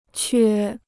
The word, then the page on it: 缺: quē